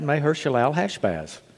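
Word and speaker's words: Maher-shalal-hash-baz: We're going to look at one of the sons this morning, and if you want to know what Mahershalal Hashbaz means, that's the other son. Quit turning your head like that. I said it right. Mahershalal Hashbaz.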